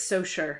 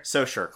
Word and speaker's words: Saussure: I think it's Saussure. – Saussure, cool.